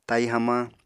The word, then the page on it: tai hamañ: apatani